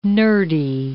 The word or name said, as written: nerdy